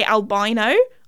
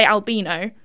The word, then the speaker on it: albino: because I think in America, you guys say albino, or maybe, like, in Canada as well, or, like, other countries. But in the UK, I swear, you say albino.